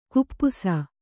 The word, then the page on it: ಖಪ್ಪಸು: Kannada